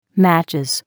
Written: matches

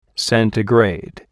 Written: centigrade

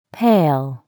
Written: pail

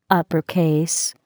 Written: upper case

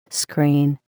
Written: screen

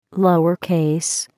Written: lower case

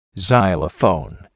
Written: xylophone

